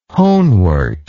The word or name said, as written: honewort